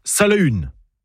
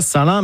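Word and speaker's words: Salaün: En breton, on prononce Salahune. Pas Salin, mais Salahune. Comme le célèbre ermite de la forêt de Landévennec, ou encore du Folgoat, près de l'Esnevin, Salahune ar Fol, Salin dit le fou.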